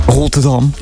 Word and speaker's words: Rotterdam: Rotterdam. Hoe spreek je dat nou uit? – Rotterdam. – Ja, dat is beter.